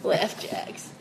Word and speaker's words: Laugh Jacks: No, but I like I said the name wrong. – Laugh Jacks. It's like Apple Jacks.